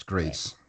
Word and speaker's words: Gris: It's written Gris, but apparently it's pronounced Grease.